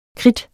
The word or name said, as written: kridt